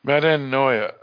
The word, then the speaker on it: Metanoia: All right, so next week we will discuss the penultimate episode, which is episode 7, and that's going to be called Melanoia. – No. You're butchering this. I looked at it earlier. Hang on. Metanoia. – Metanoia. Oh, yeah, that's a T, isn't it?